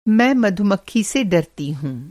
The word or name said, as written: मधुमक्खी